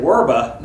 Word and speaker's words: verba: Instead of, but when they translated the word by, when Jerome used verba, but they pronounced the B's with a W sound, when he said verba, then that's very close to our word for word in English, Anglo-Saxon.